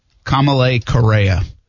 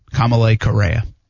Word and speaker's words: Kamalei Correa: Kamalei Correa. That's how you say it. Kamalei Correa. Kamalei Correa.